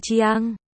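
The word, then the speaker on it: Jiang: Chữ Khương Chiang phát âm là Chiang, gần giống với Gen.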